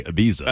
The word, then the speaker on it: Ibiza: Ibiza.